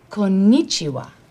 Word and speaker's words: Konnichiwa: And when he says, konnichiwa, please repeat. – Konnichiwa? – Konnichiwa. Konnichiwa, please repeat. – Konnichiwa.